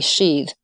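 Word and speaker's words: síd: And in early Irish sources, it was referred to as the realm of the sheath, and that's spelled S-I with an accent, D, sheath.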